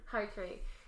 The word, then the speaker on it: harakiri: He had been out of bullets, and he committed a harikari. Is that how it's pronounced? – Harakiri.